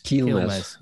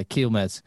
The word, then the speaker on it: Quilmes: Plenty of, how do you say that beer? – Quilmes. – Yeah, Quilmes.